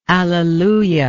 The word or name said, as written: alleluiatic